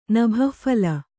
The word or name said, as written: ನಭಃಫಲ